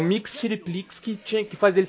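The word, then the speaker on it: Mxyzptlk: Mas que porra Não, na verdade, não tem vogal no nome dele. – Eu sei quem é. – De letras aí, a pronúncia é mais ou menos Mix Chiriplix.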